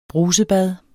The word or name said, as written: brusebad